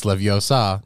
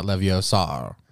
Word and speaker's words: Leviosa: Besides, you're saying it wrong. – It's Leviosa, not Leviosar.